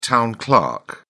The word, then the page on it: town clerk: British English